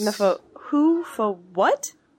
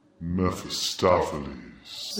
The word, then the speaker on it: Mephistopheles: Mepho-who-fo-what? – Mephistopheles. – Mepho-Waffle House? – No. Mephistopheles. – Meta-floppa... – It's best to think that the second P is silent.